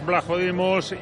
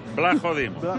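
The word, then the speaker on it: Vlachodimos: Blajodimos. – Pero se pronuncia... – Siendo portero... – Se pronuncia la V delante de la L.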